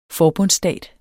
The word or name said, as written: forbundsstat